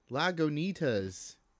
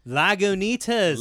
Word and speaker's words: Lagunitas: Lagunitas. – It is alcohol by volume. By the way, yeah, Lagunitas.